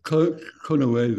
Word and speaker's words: Konoe: There were factions with Gru and with us, and it seems like in Japan and Prince Kanoe, is that how you say it? Like a settlement was very, very close to happening that might have prevented war. – Well, Konoe, they say.